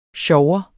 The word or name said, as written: SJOVERNES